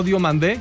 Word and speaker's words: Diomande: No Diomande, que ya dijo el chico, ¿sí? – Que se pronuncia así.